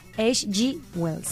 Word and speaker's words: H.G. Wells: Y falleció en el año 1946 el escritor británico de ciencia ficción H.G. Wells.